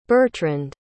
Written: Bertrand

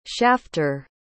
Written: shafter

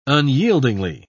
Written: unyieldingly

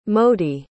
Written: mody

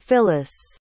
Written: phyllis

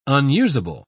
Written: unusable

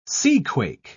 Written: seaquake

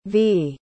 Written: vea

